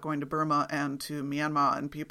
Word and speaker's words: Myanmar: spelled M-Y-A-N-M-A-R, but pronounced by all the people who live in the country as Myanmar or Myanmar.